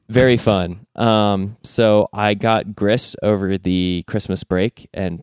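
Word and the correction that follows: Gris: It is now pronounced Gree.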